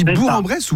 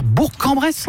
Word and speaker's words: Bourg-en-Bresse: Moi j'étais comme toi, je disais Bourg-en-Bresse. – Non. Et il faut dire Bourg-en-Bresse. – Ouais, Bourg-en-Bresse.